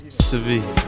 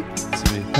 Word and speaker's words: Tzvi: My name is Tzvi. – What's Tzvi? – Tzvi. – How do you spell that? – T-Z-V-I. – T-Z-V-I.